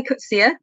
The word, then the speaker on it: Coetzee: I would look it up on as like Coetzee, but you pronounce it Coetzee, is that it? – I think I've heard different pronunciations. I say Coetzee. – Coetzee, okay. – I've seen him talk in person, and he used that, but I think it's absolutely fine to say either.